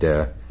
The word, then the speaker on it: Te: That is spelled T-E, but I'm told it's pronounced de.